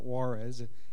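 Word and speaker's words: Juárez: It's a J, but you pronounce it like an H. So it's not Juarez, it's Juarez.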